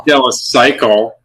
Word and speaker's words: Keuchel: And you mispronounced his name. – Dallas Keuchel? – Yeah. – How do you pronounce Dallas Keuchel? – Dallas Keuchel. As in Cy Young? – Cy Young.